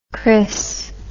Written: cris

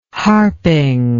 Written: harping

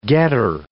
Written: getter

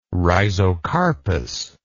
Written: rhizocarpous

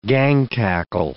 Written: gang-tackle

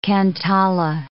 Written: cantala

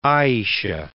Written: Aishah

